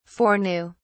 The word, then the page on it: foreknew: British English